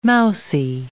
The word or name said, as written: mousey